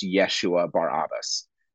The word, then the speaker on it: Yeshua Barabbas: Or in Aramaic, it would have been pronounced Yeshua Barabbas, something along those lines.